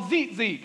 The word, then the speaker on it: tzitzit: In the Hebrew, they're spelled T-Z-I-T-Z-I-T. Now, I read that as T-Z-Z-I-T, but that's not how you say it. That's not it. It's called Zitzi. Can you say that?